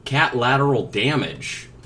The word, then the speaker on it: Cat Lateral Damage: Okay, it's called the Cat Lateral Damage. It's actually in the video game category. – And it's like collateral, but it's spelled with a cat. – Yeah, C-A-T.